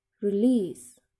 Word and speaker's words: release: Di sini contohnya release. Jadi penekanannya dia di kata list, release. Ditekankan di yang kedua.